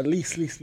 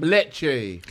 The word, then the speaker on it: Lecce: No, Lice. – Oh, my bad. – Lecce.